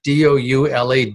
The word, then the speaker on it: doula: D O U L A doula.